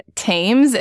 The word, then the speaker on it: Thames: Thames, and that's T-H-A-M-E-S, rhymes with James.